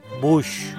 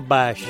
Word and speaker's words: Bush: Bush, no Bash, no nos inventemos el inglés. Kate Bush, arbusto, ¿eh? en inglés, como George Bush. Se escriben igual, por, lo tanto, se pronuncian igual.